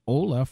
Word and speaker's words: Ola: Yes, Ola. Like, hello.